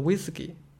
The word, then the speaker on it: WSGI: 我我直译就把它翻译成了一次一滴，然后我就想到了 whisky，就是 WSGI 这个标准为什么喜欢被读作 whisky，然后为什么就有 bottle 这个框架，然后 flask 这个框架，他们都是用容器来做名的，然后想到他们原来是是要装 whisky 的，就是 Python 的那个协议 whisky，他们要用来装 whisky 的，然后我就想到这不会不会是一个用来喝酒的容器吧？然后我就又去问了阿米周纳克尔，还告诉我这是一个 drinking hall，不是一个旁的 hall，就是用来喝酒的容器。